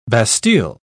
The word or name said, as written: bastille